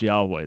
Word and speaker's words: Yahweh: Do you agree that the Hebrew was Yahweh? – I thought in Hebrew was the four-letter word, the tetragrammaton. – That's rightly pronounced Yahweh. That's the best understanding of how it's pronounced.